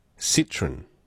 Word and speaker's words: Citroën: Citroën is a French car pronounced Citroën. Citroën? Not Citroën. It's not a citrus fruit, it's a car.